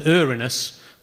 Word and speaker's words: Uranus: And in any case, you might add, it's actually pronounced Uranus, so your joke doesn't work.